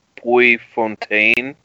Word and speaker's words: Puyfontaine: Puyfontaine.